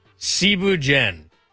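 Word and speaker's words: Thieboudienne: First off, Cebu Jen. It's spelt like Thibu Dien.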